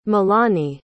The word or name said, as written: milani